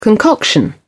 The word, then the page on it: concoction: British English